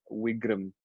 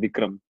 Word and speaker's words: Wigram: I mean, uh, Harris Park has a street called uh, Wigram, which, which is spelled W I G R A M, but everybody, everybody, every Indian calls it Vikram, Vikram Street, because it's literally a street which has only Indian restaurants.